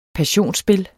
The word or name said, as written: passionsspil